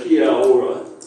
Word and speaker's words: kia ora: If you say kia ora, whatever.